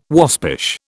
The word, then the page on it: waspish: British English